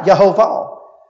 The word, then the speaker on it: Yehovah: The word Lord is the Hebrew word Yehovah, if I read the pronunciation key right, Yehovah. It's what we say, Jehovah.